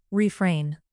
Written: refrain

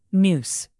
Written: noose

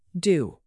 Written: due

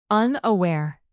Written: unaware

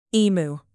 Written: emu

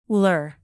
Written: lure

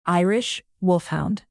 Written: irish wolfhound

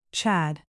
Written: Chad

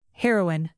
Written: heroine

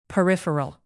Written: peripheral

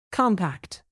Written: compact

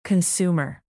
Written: consumer

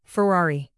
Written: ferrari

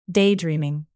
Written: daydreaming

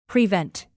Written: prevent

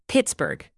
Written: pittsburg

